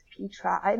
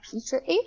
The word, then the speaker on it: Petri: And Alexandra Petri, which I just learned is pronounced Petri, not Petri.